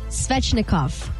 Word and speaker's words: Svechnikov: Andre Svechnikov.